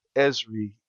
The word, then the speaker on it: Esri: But what we take it a step further, since we have the software engineering background, Esri is, mm-hmm.